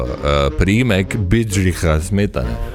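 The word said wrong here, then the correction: Bedřich Smetana: Okay. – Bedrih smetana. Bedrih smetana.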